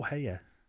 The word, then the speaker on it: Häyhä: Okay, so Simo Heyer, however it is pronounced, H-A-Y-H-A. – I think it would be pronounced Heyer.